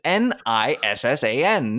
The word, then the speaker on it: Nissan: Because ah. that's how it's spelled: N I S S A N. – Nissan. It's like you and saying. That's how you say it everywhere else in the world. Instead of Basil, Basil. in the English-speaking world, at least.